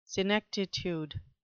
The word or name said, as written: senectitude